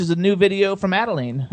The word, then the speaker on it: Adalene: It is Adalene. We do get Adele, Adeline, but it's Adalene.